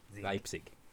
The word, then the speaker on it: Leipzig: There's no B in it. Like, for Christ's sake. – Oh my God. I just actually see it there. Leipzig. – There you go. – Welcome to German with Colin. – That's not even German. It's literally how the word is spelled. You're putting a B in there. It doesn't exist.